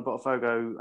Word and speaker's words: Botafogo: Botafogo, yeah.